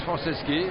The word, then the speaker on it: Franceschi: Là on est avec Patrice Franceschi, comment on prononce votre nom? – Patrice Franceschi, voilà.